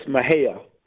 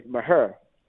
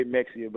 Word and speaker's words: Mexia: It's Mahia. That's how it's said. And if you're from the country where I consider Mahia to be, we say Maher. And if you are somebody that's not from Maher, is Mexia. – It looks like Mexia, spelled. Exactly. So people who don't know it say Mexia, but it's Mahia.